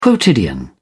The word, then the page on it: quotidian: British English